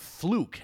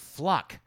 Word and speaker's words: Fluke: You know, one thing that's interesting is that her name is spelled Fluke, F-L-U-K-E, but every time Fox News talks about her, they pronounce it Fluck, which rhymes with fuck.